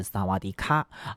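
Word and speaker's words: 萨 瓦 迪 卡 (สวัสดีค่ะ): 你一定很熟悉，因为如果加上卡，就是《萨瓦迪卡》，你好，对不对啊？但其实呢，这个泰语哈、啊、似乎有这个阴性阳性的这个区别，女生啊讲你好是萨瓦迪卡啊，但是男生呢啊这个发音要是萨瓦迪 club。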